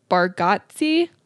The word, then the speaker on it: Bargatze: And then my second one this week is, I can't say his last name because I am who I am. Nate Bargatze. Italian pronunciation of that.